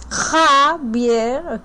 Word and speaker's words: Javier: I have to say the name of the director is Javier Aguirre, you know? I know these are very, very difficult sounds for a non-Spanish speaker, but it's Javier, okay?